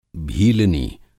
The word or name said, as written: भीलनी